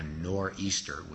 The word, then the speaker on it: nor'easter: And as he's writing this poem, what we call in New England a nor'easter, which is a corrupt English pronunciation for northeaster, a northeastern storm called a nor'easter sweeps into New England.